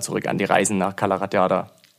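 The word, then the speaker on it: Cala Ratjada: Es müsste Calaratiada sein. – So einfach spricht man das, Calaratiada? – Äh, ja, ja, okay.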